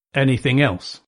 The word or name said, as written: anything else